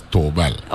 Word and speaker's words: Theobald: Theobald Moorhead, Cleone Creaky, and everybody. Yeah, yeah. But I'll say that the Virgin Islands. – Let me correct you going forward, right? It's spelled Theobald, but it's Tobal.